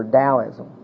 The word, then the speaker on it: Taoism: Well, that's the symbol for Taoism. It's pronounced Taoism and spelled with a T.